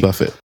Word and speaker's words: Buffett: It's by Alan Jackson with Jimmy Buffett. – Fuck Jimmy Buffett. – It's pronounced Jimmy Buffet.